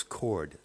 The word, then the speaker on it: Cord: I referred to the husband of Mary Pinchot Meyer, that's spelled M-E-Y-E-R, so it could be pronounced Mayer, I suppose, as Court, but his name was Cord, C-O-R-D, Meyer.